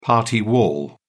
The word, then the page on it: party wall: British English